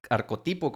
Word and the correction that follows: arquetipo: ¿cómo se dice? arquetipo. Arquetipo.